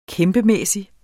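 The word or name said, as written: KÆMPEMÆSSIGT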